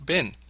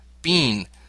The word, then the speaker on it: been: B-E-E-N. But in an American accent, this is often pronounced bin. In an English accent, this may be pronounced as bean, a homophone of B-E-A-N, or just as bin, depending on where you go.